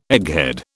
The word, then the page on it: egghead: British English